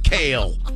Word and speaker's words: Kale: K-A-L-E. K-A-L-E.